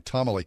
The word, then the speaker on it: tomalley: Tomally. It's spelled T-O-M-A-L-L-E-Y. Tomally.